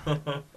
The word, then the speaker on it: Basinger: Like, how do you say that name? Basinger. – Basinger.